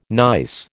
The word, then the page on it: gneiss: American English